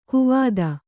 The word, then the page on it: ಕುವತ್ತು: kuvattu